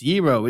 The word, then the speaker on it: gyro: It's not pronounced that way. It's Yee-ro. It's Yee-ro. It's Yee-ro. It's Yee-ro. Hey, I think you should pronounce it Yee-ro. Like, I don't understand. Like, do you think this person read the first 87 comments where someone said it's pronounced Yee-ro, right?